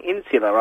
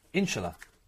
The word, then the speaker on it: inshallah: Third point, why do you keep saying insular after every single thing? – Inshallah. – What's insular? – Inshallah. – You know, the proper pronunciation is inshallah.